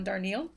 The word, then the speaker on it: Darnielle: John Darnielle.